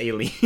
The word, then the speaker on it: Aileen: it's actually pronounced Aileen.